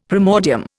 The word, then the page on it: primordium: Indian